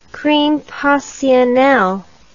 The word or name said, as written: crime passionel